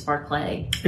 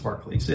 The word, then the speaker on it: Barclays: And it's pronounced Barclays. It's spelled Barclays.